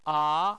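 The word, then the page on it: a: tone 2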